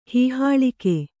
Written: ಹೀಹಾಳಿಕೆ

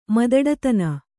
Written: ಮದಡತನ

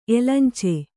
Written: ಎಲಂಚೆ

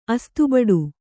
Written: ಅಸ್ತುಬಡು

